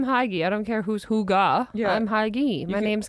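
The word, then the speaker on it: hygge: It's pronounced Hugga. – Hugga. – Hugga. Oh my gosh, I don't like that. I like Huggy better. – I'm calling it Huggy. – Hugga? Okay, but we just made a lot of people happy that are sitting there being like, it's Hugga. – How did you find out it's Hugga? – I said, how do you pronounce Huggy? – And what does it say? – It says H-O-O-G-A-H. – Hugga.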